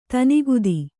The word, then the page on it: ತನಿಗೆತ್ತು: tanigettu